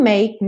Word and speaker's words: make: Make, não pronuncia a letra E. Para no k, k, som do K, make.